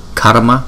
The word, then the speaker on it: karma: Sarva, sarva, sarva, karma, karma, karma. Chúng ta đọc phải có chữ R trong đó ha.